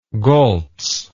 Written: goltz

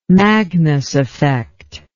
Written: magnus effect

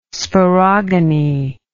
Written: sporogony

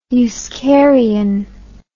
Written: euskarian

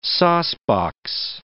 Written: saucebox

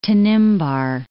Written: Tanimbar Islands

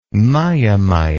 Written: miamia

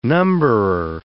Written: numberer

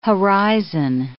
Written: horizon

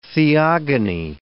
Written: theogony

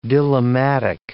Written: dilemmatic

